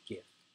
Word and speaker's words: GIF: Yes, I said it. Gif. It's called. It's pronounced gif.